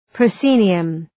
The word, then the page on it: proscenium: prə’si:nıəm